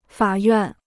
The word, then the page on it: 法院: fǎ yuàn